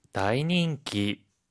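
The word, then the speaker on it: 다이닝기 (大人気): 다이닝기, 다이닝기. – 이때 다이는요, 클 대라고 하는 한자를 써요. 매우 커다란 엄청난 이란 뜻을 나타내거든요. 그런데 이클 대는요, 음으로 읽을 때는 다이, 뜻으로 읽을 때는요, 오라고 읽어요.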